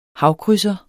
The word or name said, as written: havkrydser